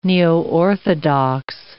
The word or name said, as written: neoorthodox